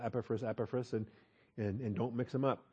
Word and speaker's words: Epaphras: Let's talk about Epaphras. Epaphras, Epaphras, Epaphras. I've heard the accent on all 3 syllables. Greek puts the accent on the final alpha, so I guess we should call him Epaphras.